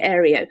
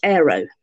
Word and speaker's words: Areo: I think it can go either way. It's for Ario, Milton's Ario Pagitica, and I'm a Londoner, so I say Ario. Iona, of course, is half Scottish, like you, and she pronounces it Ario. So I'm not too bothered by that, but please don't anybody call it Aero. A-E-R-O is not the correct spelling, and it's extremely annoying.